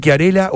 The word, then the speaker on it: Chiarella: Y bien pronunciado es Chiarella. – En italiano sería Chiarella. – Chiarella. – Eso, ahí está. – Claro, pero es Chiarella. En argentino es Chiarella.